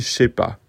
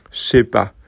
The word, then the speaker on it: je sais pas: Pour vous donner une idée de la prononciation, c'est un petit peu comme CH, E accent, plus loin PAS. Donc je sais pas.